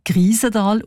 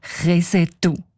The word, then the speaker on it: Kriesental: Der Ueli Segesser schreibt, ein älterer ortskundiger Mann habe den Namen allerdings nicht als Kriesental ausgesprochen, sondern als Kresetu.